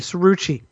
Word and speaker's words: Cerucci: It's a soft C at the beginning. Cerucci.